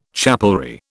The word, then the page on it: chapelry: British English